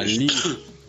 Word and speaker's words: Lire: Да, L-I-R-E, по-моему, да? – Да, Лирия. Так и пишется.